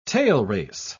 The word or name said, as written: tailrace